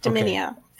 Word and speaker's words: Dominia: And am I pronouncing that correctly? – Yes, Dominia, okay. Dominia. 4 syllables.